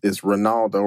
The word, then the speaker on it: Ranauldo: So, you know, you can literally find me on Instagram with my name, literally, which is Ranauldo Wright, which is spelled R-A-N-A-U-L-D-O. And my last name is Wright, W-R-I-G-H-T.